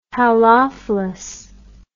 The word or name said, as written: halophilous